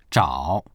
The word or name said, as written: zhao3